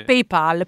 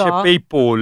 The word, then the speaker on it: PayPal: Gente che dice PayPal. – No, non si dice PayPal. Non è Paul. – Non è Paul Newman. – No, non è Paul McCartney. – È Pal come amico.